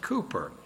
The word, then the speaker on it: Cowper: He was a man in England named William Cowper, spelled Cowper, but the English pronounce it Cooper.